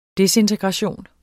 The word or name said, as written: disintegration